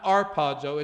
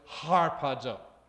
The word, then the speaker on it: harpazo: So put an H there. It's not arpazo, it's harpazo. It's a strong alpha, okay? We don't mean by character, but we mean by pronunciation, okay? That's harpazo.